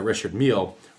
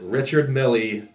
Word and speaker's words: Richard Mille: But my favorite part of life that was brought forth in regards to Richard Mille was with my one of my favorite rap songs, Meek Mill Going Bad, where Meek Mill butchers the pronunciation of Richard Mille and calls it Richard Millie cost a Lambo.